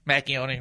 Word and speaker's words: Macchioni: And then I had a line about Pamela, you know, Macchioni, rhymes with macaroni.